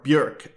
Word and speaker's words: Björk: Here's an eccentric from the pop world, the Icelandic singer-songwriter Björk, or Björk, as I think is the more correct pronunciation.